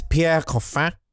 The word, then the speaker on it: Pierre Coffin: I'm sorry, it's Pierre Coffin. And I will not hear it any other way for the rest of this program.